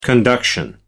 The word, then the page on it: conduction: American English